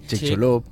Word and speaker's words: Chicxulub: Chicxulub. – Chicxulub. Però és que és un nom maia, i haurien de posar accent maia. Chicxulub